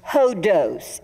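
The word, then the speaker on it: hodos (ὁδός): And the Greek word for road or way or route is a word pronounced hodos. It's H-O-D-O-S phonetically.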